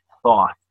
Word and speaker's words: Thoth: It's the word T-H-O-T-H, thought. And you have to say it with your tongue between your teeth, like this. Yep, you got it for the first spell it again. – T-H-O-T-H.